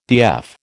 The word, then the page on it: DF: American English